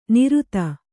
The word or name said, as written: ನಾರೆತ್ತು